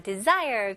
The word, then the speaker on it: desire: 네, 여기 스펠링은 de이지만 di로 생각하시면 좋아요. desire가 아니고요. de, 그 다음에 s는 여기서는 z 발음이 나와야 돼요.